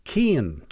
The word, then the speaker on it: Cian: And the other pronunciation is keen. – Keen. – As in, same way you would say, like, you know, I'm very keen on playing tennis or whatever. Except instead of K-E-E-N, it's C-I-A-N, keen.